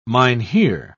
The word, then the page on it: mynheer: British English